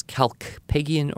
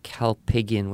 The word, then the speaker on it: Kalpakgian: So his spell his name, K-A-L-P-A-K-G-I-A-N. – I'll say that again. K-A-L-P-A-K-G-I-A-N. – It's Greek, and it's Kalpygian or Kalpygian with a silent K. – Oh, okay. – As I had a conversation with his son. – Oh. – Who actually works for the classical academies in Southern California, who we do quite a bit of business with. And I talked to his son on the phone, and he said, yeah, you can pronounce it either way.